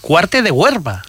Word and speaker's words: Cuarte de Huerva: No es que esté pronunciándolo mal, sino que es que se llama Huerva, la localidad de Zaragoza. – Huerva. – Cuarte de Huerva, sí señor, con R.